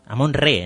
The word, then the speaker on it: Amón-Re: Amón-Re en realidad. Se pronuncia Re, no Ra.